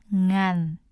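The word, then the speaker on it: ngàn: 간. – 간. – 간. – 응한. – 네. 자, 응하는 스펠링이 N, G, A, N. 그 다음에 내려가는 성조를 A 위에 붙여주시면 됩니다.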